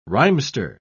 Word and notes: rhymester: British English

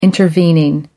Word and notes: intervening: American English

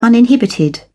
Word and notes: uninhibited: British English

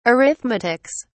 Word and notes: arithmetics: British English

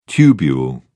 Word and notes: tubule: British English

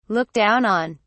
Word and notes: look down on: British English

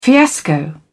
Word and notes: fiasco: British English

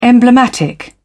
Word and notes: emblematic: British English

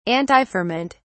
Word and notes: antiferment: British English

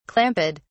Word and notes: clamped: British English

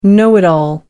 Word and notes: know-it-all: British English

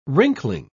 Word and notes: wrinkling: British English

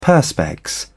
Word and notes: perspex: British English

